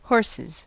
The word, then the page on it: horses: American English